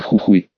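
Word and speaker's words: Jujuy: Особенно много наших в провинции с романтичным названием Жужуй-37, по-испански название провинции произносится, как Хухуй.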